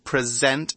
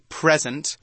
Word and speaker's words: present: Remember when we're pronouncing this word in the verb form, we say present, emphasis on the second syllable. Not like when we say the noun form, we say present. Same spelling, very different words.